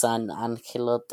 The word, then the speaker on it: San Angelo: Or Angelo, I guess. I'm just saying it the Latin, like... – You're saying how it's supposed to be pronounced, so that's true. Okay. – Okay, then it's San Angelo, Texas.